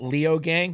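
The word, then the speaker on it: Leogang: You had a second place at Leo Gang. Did I, yeah, pronounce that right? – Yeah, you did, yeah.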